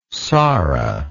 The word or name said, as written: sara